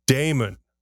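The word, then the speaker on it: daemon: The word is pronounced Damon with Eamon. A long A.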